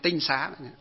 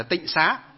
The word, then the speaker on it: tinh xá: Chứ tinh nó có chữ bộ mễ và bộ thanh, bộ mễ và bộ thanh, chứ tinh xá. Sau này họ đọc là tịnh xá, họ đọc là tịnh có nghĩa là sạch sẽ, nhưng thực tế trong nó bước đầu gọi là tinh xá.